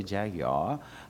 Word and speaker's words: Jaguar: Okay, it's nice to have a Maserati or a Jaguar, as the British say, Jaguar.